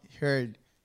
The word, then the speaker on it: Heard: Y Amber Hard. Amber Hard. Hard. Hard. Heard. Heard. No sé cómo se dice la apellido. Es e H-E-A-R-D. Heard. Heard, Heard. Amber Heard.